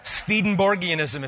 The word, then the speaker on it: Swedenborgianism: Swedenborgianism, if you want to make it correct with the W pronounced as a V.